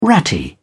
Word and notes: ratty: British English

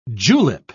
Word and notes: julep: British English

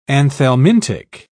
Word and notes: anthelmintic: British English

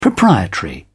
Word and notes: proprietary: British English

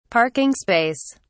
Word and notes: parking space: British English

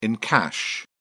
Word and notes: encash: British English

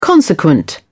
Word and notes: consequent: British English

